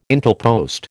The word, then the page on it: Intelpost: American English